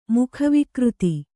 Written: ಮುಖವಿಕೃತಿ

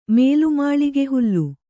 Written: ಮೇಲ್ಮಾಳಿಗೆಹುಲ್ಲು

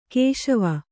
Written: ಕುಶವ

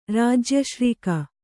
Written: ರಾಜ್ಯಶ್ರೀಕ

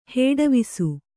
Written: ಹೇಡವಿಸು

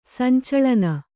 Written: ಸಂಚಾಲನ

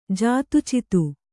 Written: ಜಾತುಚಿತು

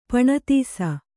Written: ಪಣತೀಸ

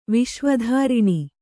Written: ವಿಶ್ವಧಾರಿಣಿ

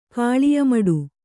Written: ಕಾಳಿಯಮಡು

